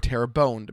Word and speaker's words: Terrebonne: This storm affected areas like Terrebonne, or Terrebonne, depending on how you want to pronounce it.